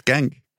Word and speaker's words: Genk: I think I can't really do anything with Genk. It's Genk. Genk's Genk.